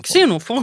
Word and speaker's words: Xenofon: De grekiska historieskrivarna Herodotos och Xenofon. Uttalar man det så? – Xenofon. K-S.